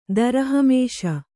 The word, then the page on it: ದರಹಮೇಷ: kannada